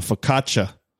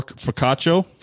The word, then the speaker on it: focaccia: The focaccia. Right? That's how you say it? – Yeah. – What did I say before? Focaccio? – Focaccia. – Focaccia. That's what I used to say.